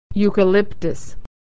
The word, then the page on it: euchalyptus: y